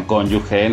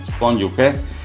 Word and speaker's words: cónyuge: Como de que los hijos sean armas y sean pleito y que gracias a los hijos se puedan manipular al cónyuge, digan cónyuge, no digan cónyuge, se escribe con G, no se escribe con G, I, U, ¿no? Cónyuge.